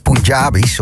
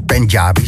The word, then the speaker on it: Punjabi: Dit liedje is in het Punjabis, of Punjabi, zo spreek je het uit geloof ik.